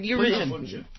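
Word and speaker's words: Gisele Bündchen: Giselle Bundchen.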